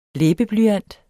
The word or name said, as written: læbeblyant